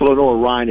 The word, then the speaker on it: Lenoir-Rhyne: It's, I think it's Lenoir-Rhyne is the way you pronounce it. I think Correct.